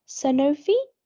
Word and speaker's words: Sanofi: And I wanted to get into, so you were at, correct me if I'm pronouncing this wrong, Sanofi? – Yeah.